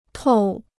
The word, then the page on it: 透: tòu